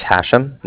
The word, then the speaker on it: Hashem: All right, my name is Hashem. That's H-A-S-H-E-M, as in mother at the end.